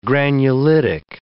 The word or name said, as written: granulitic